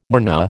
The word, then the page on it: mRNA: American English